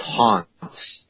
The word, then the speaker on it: Hans: It's pronounced Hans. – Oh, no, not in America, it's not. Well, I should say this. – Well, if we're doing this. Oh, wait a second. If his son, old lady Lejeune, said, my name is pronounced Lejeune. – If his dad says, but see, if it's just German pronunciation and I'm an American.